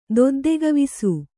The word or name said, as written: ದೊದ್ದೆಗವಿಸು